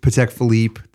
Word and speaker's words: Patek Philippe: Number two, Patek Philippe. Did I say that correctly? – Yeah, that's pretty good.